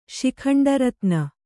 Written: ಶಿಖಂಡರತ್ನ

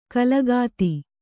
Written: ಕಾಲಗತಿ

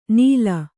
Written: ನಿಲ್

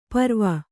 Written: ಪರ್ವು

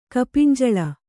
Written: ಕಪಿಂಜಳ

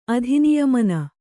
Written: ಅಧಿನಿಯಮನ

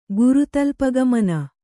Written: ಗುರುತಲ್ಪಗಮನ

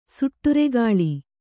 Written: ಸುಟ್ಟುರೆಗಾಳಿ

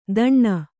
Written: ದಣ್ಣ

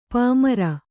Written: ಪಾಮರ

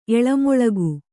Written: ಎಳಮೊಳಗು

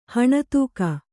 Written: ಹಣತೂಕ

